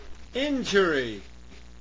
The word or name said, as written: injury